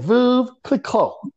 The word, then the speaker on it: Veuve Clicquot: Oh, man, let me mispronounce this again. We drinking that Veuve Clicquot. I know I am. I got, if anybody is French listening to this, sorry. Babe said, Veuve Clicquot. I messed it up again.